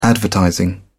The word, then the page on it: advertising: British English